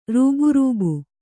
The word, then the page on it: ರೂಬ್‍ರೂಬ್: kannada